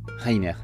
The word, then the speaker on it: Rainer: Ce sera Rainer comme Rainer Maria Hilke. Il n'y aura qu'à le prononcer à la française.